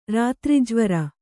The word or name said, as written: ರಾತ್ರಿಜ್ವರ